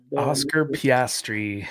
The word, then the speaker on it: Oscar Piastri: Oscar Piastri.